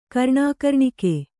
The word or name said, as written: ಕರ್ಣಾಕರ್ಣಿಕೆ